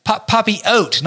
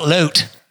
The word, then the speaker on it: papillote: So E-N, and then the second word is P-A-P-I-L-L-O-T-E. – L? Where the freaking L come from? What are these silent two L's? What are these? Papillote, not loot. – Yes, papillote. En papillote. – Freaking stupid French. Silent letters.